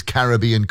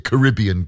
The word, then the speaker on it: Caribbean: In the middle from the Sugar Babes, and there's Billy Ocean with his Caribbean Queen. Or is it Caribbean Queen? Doesn't really matter how you pronounce it, because if you win this afternoon £750,000, you can go there.